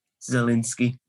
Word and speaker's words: Szalinski: Imagine the way I think of it is, imagine there's no S, it just begins with a Z. Szalinski.